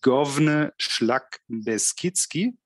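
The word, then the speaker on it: Główny Szlak Beskidzki: Actually, yesterday I asked a native speaker, and I now hope that I will not butcher it. And it's Govne Szlak Beskidzki.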